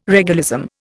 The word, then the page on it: regalism: Indian